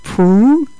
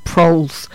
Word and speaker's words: Proulx: Sounds like I'm one of those girls off, that comedy show, Prue and Trudy. But we looked up how to pronounce it, and that's right. I always used to say it Proulx.